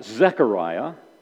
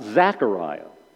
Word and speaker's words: Zechariah: Let's turn God again, please, to the book of Zechariah. Just a little pastoral pet peeve. This is a book that's pronounced Zechariah, not Zachariah.